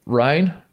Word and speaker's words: Rhein: Wait, is that how you pronounce Rain? R-H-E-I-N? The Rain Fire. – Rhine? – Is it Rhine? I always said Rain.